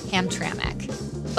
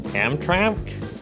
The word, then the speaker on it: Hamtramck: If you're from here, you know it's pronounced Hamtramck. But it can be a tough word for out-of-towners to get right. Here are a few of them, bless their hearts, giving it their best shot. – Hamtramck? – Hamtramck? Hamtramck. – They're close. They're so close. But they're not picking up on that invisible vowel sound that us locals insert between the M and the C to get it to come out as Hamtramck.